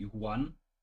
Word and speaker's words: Yuan: Also und Huan, so das klingt jetzt wie mit Habe, das ist halt einfach die Aussprache, ist in dem Fall Y, Y-U-A-N. Das ist auch, glaube ich, einer also der aktuelle Name einer Währung, der Yuan. Genau, das wird so Yuan ausgesprochen, glaube ich, oder?